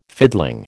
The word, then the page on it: fiddling: American English